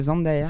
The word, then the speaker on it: Zendaya: Non, parce que elle a fait des interviews, elle en a marre que les gens se trompent sur son prénom, elle appelle Zendaya alors que c'est Zendaya.